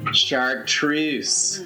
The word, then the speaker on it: Chartreuse: Chartreuse.